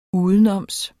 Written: udenoms

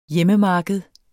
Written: hjemmemarked

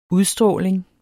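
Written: UDSTRÅLING

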